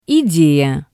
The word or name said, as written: ideya